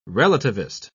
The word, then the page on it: relativist: British English